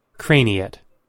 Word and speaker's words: craniate: C-R-A-N-I-A-T-E. What is this? – Craniate. – Craniate. – Craniate. – Craniate. – Craniate. – I don't know that one. – Craniate.